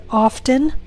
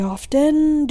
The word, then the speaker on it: often: Often, I am pronouncing the T. But in the second example, my T is silent, often. So the question is, which pronunciation is correct? And the answer is, both of them are correct.